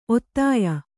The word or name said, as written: ಒತ್ತಾಯ